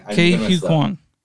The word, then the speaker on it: Ke Huy Quan: Ke Huy Quan.